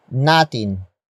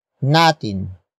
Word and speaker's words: natin: The next word is natin. Natin. Natin. Natin is spelled as N-A-T-I-N.